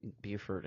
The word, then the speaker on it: Beaufort: The French Beaufort, right. – B-E-A-U-F-O-R-T. But locals call it Beaufort. And that's just how you say it. But in North Carolina, they had the audacity to have a Buford up there, but they call it Beaufort instead.